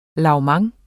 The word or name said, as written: lavement